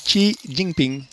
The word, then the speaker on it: Xi Jinping: Je pense que c'est le président Xi Jinping. – Attends un peu, fais attention comment tu prononces son nom. – J'avoue que je n'ai pas l'accent. – Son nom, c'est Xi Jinping.